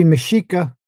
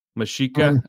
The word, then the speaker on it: Mexica: We use it to refer to a whole civilization, but the Mexica and the Nahua are going to be, am I saying this correctly? – Yeah, it should be Mexica. – Mexica, okay.